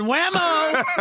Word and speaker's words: Waymo: It's Waymo. Yeah, Waymo. – Hey, hold on a second. Trey said Waymo.